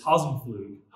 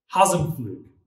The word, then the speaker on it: Hasenflug: See, my family always said that the P was silent. They would just say, it's Hasenflug, Hasenflug. But the P isn't actually silent. It's just awkward to say. Yeah. In fact, it's pronounced Hasenflug.